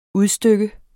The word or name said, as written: udstykke